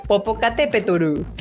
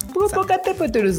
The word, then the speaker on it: Popocatépetl: cagado, sí lo pronunció bien en japonés, pero pues lo pronuncian Popocatépetl. – Popocatépetl.